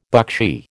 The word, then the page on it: buckshee: American English